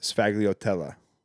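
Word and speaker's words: sfogliatella: It's spelt S-F-A-G-L-I-O-T-E-L-L-A. It's pronounced sfagliatella, and it's an Italian thing.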